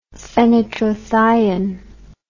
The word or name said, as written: fenitrothion